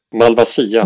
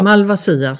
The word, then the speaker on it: Malvasia: Men du, det finns en, en eh, druva som jag har tal, talas om som heter Malvasia, heter den så? Uttalar man det så? – Malvasia. – Malvasia såklart.